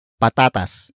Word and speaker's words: patatas: And that is patatas. Patatas. Patatas. Patatas is spelled as P-A-T-A-T-A-S.